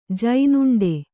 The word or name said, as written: ಜೇನುಂಡೆ